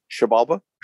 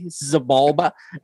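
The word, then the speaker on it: Xibalba: I've always said it the white boy way. Zabalba. Exabalba. Yeah, I've always called it Zabalba, but I've been friends with you guys for so long that I figure I need to start saying it right.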